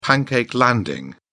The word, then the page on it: pancake landing: British English